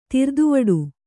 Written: ಧೀರತೆವಿಡಿ

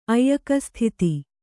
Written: ಐಲಕಸ್ಥಿತಿ

